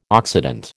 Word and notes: oxidant: American English

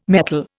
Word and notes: metol: Indian

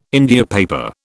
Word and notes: India paper: British English

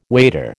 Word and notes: wader: American English

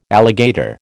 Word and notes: alligator: American English

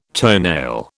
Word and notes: toenail: British English

